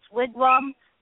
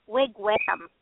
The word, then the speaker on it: wigwam: Like I heard, I found out recently that people in the UK pronounce wigwam wigwam. Really? Yeah. They're like, no, no, it's wham, you know, like rhymes with Pam. I'm like, no, it's wham. it rhymes with mom.